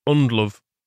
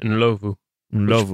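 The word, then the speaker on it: Ndlovu: Yeah, on that pronunciation, the very Yorkshire Undlove is how I always knew it, but I did work with someone from Zimbabwe and he pronounced it Nlovu, which is probably more accurate.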